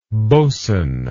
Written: bosun

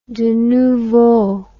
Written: de nouveau

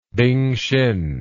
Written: bing xin

